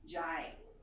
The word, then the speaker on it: jay': Klingon accomplishes the same kind of deal with jai, and you just stick it at the end of the sentence. It's a separate word. It is spelled J-A-Y apostrophe, which is a glottal stop, and it just makes everything.